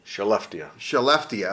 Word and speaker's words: Skellefteå: I know I'm going to butcher it, but he plays in Sweden, and it's, is it Skellefteå? – Skellefteå. – Okay, great.